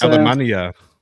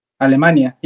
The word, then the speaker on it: Alemania: Alemania. That's how it's pronounced in Spanish, right? – Alemania, yeah.